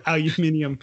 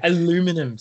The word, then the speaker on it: aluminum: It's a phobia of aluminium. Anyway. – Aluminum.